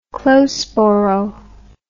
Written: close borough

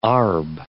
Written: arb

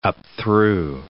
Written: upthrew

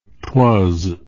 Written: twas

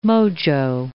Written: mojo

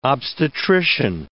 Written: obstetrician

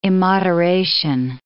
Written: immoderation

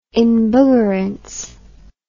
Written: embuggerance

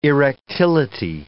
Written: erectility

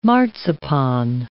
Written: marzipan

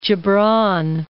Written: Jibran

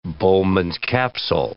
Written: Bowman's capsule

